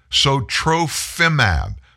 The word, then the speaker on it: Sotrovimab: Sotrovimab, S-O-T-R-O-V-I-M-A-B,